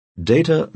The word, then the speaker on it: data: People who deal with data professionally know that the first syllable of data rhymes with rate, not rat.